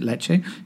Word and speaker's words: Ledaig: It's spelled in a totally different way, L-E-D-A-I-G, but we pronounce it Leche.